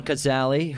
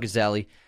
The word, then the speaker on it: Cazale: And then John Cazale, who, John Cazale, Cazale.